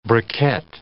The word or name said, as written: briquette